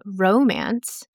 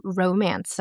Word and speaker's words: romance: So when we're talking about the noun romance, we stress the ro.And then when we're talking about the verb to romance someone or to be romanced, you would stress the second syllable, mance.